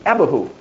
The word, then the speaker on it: Abihu: And the one I want to concentrate now is found in right ways and wrong ways is Leviticus 10, Nadab in Abihu, or the correct way to say it, as Brother Brett knows, is Abihu.